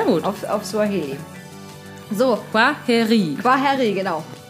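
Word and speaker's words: Kwaheri: Äh, Quaheri. Quaheri? Ja, genau. Das I spricht man länger hinten und das KW vorne wie ein Qua.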